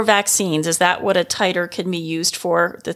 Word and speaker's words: titer: And it's not teeter, it's not titter, it's titer. And it is spelled two ways, T-I-T-E-R, or I think it's the British spelling, which is T-I-T-R-E.